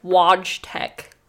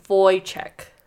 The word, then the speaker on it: Wojtek: So it's like the guy's name is spelled like Wojtek, but it's Wojtek.